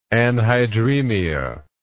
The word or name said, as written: anhydremia